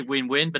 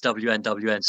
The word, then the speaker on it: WNWN: Yeah, so it's written WNWN. We say Win-Win, but about 50% of people slightly embarrassingly just read it out as WNWN.